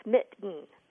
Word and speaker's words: mitten: But the standard pronunciation is that second vowel isn't pronounced. It's mitten. Mitten.